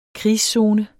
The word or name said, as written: krigszone